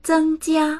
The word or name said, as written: zeng1jia1